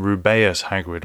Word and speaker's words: Rubeus Hagrid: And in an interview with Jo Rowling, she actually pronounces his name Rubeus Hagrid, which might be related to the fact that apparently his name is inspired by the colour red or the word red, probably.